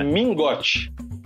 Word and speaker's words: Mingote: É, o Minhote, é, Minhote, como é que fala? Minhote, né? Mingote. Mingote.